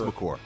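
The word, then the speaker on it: Makur: Number Trey, McCourt, is that how you say it? Is it McCourt? – McCourt. – It is McCourt.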